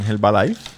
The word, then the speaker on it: Herbalife: que venden Herbalife. Y, y, les va súper bien. Que en verdad no se dice Herba, se dice Herbal. Herbalife.